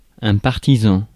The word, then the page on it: partisan: France; IPA: [paʁ.ti.zɑ̃]